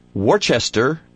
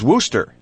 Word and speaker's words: Worcester: And she found this and wrote to me, according to the Oxford English Dictionary, the I is silent in American pronunciation. It is no more incorrect to pronounce poinsettia as poinsettia in the United States than it is to pronounce Worcester as Worcester in England.